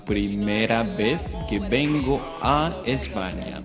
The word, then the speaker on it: España: Just simply remember how to pronounce Spain in Spanish as España with an ñ on it.